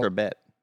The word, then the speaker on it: sherbet: Sherbet. – No, I'm saying it right. Sherbet.